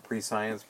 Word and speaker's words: prescience: Coincidence or prescience? I can never pronounce that word. Prescience, prescience. – Prescience. I think that's the way it's pronounced.